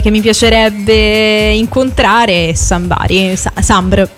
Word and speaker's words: Sombr: Guarda, in realtà credo che sia più corretto dire Sambari, però la pronuncia che lui stesso dà al suo nome sarebbe Sombr.